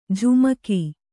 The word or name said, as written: ಝಮಕು